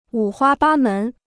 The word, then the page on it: 五花八门: wǔ huā bā mén